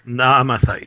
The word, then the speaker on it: Naamathite: Now, how do we pronounce these names? So Eliphaz. – Hatemani from Teman. Mm-hmm. We have Vildad. From Shuhi. And we have Tsofar. Right. – The Naamathite. Is that right? – Right. Naamathite, yeah.